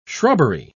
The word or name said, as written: shrubbery